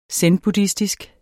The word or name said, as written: zenbuddhistisk